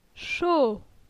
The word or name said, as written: Cho